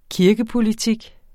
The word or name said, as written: kirkepolitik